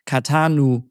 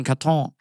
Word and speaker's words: Catan: So in some languages, I think in Czech, it needed to be called Catanu. And in French, they added an E so that the pronunciation is Catan. Otherwise, it would have been Caton.